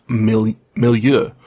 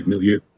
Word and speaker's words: milieu: Like, the one that came up over and over again was milieu, is the way it's supposed to be pronounced, I think. Milieu? – Yeah, milieu.